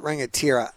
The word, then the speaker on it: Rangatira: That's how you pronounce it, Rangatira.